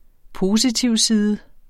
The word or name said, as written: positivside